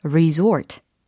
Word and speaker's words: resort: 아, 리조트는 영어로 리조트가 아니었어. 그쵸? 예, 영어 발음으로는 resort, resort, resort.